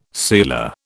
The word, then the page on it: sealer: British English